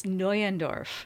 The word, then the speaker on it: Neuendorf: It's Neuendorf. – It is. Okay, see, you see the N-E-U thing, and I've met news, noise, and nyes, and I appreciate you clarifying for me.